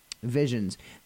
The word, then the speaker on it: V1S10NS: And Visions. Their name is spelled really weird. It's V1S10NS.